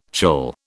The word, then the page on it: chill: British English